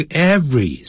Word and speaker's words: every: Every morning，不要念成 every morning，不是三个音节，那个 every 是两个音节，every。不要念成 every，没有这样念法的。